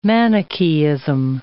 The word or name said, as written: Manichaeism